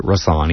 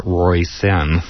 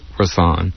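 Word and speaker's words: Róisín: Her name is spelled R-O-I-S-I-N. It's pronounced Rosanne, even though it's Roy Sin, but it's Rosanne.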